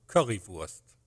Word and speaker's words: Currywurst: So, for example, Currywurst.